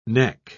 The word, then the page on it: nek: British English